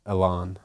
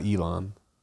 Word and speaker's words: Elon: You saw that Zuck was saying that Elon was, you know, by the way, that's how you pronounce it. Elon, not Elon.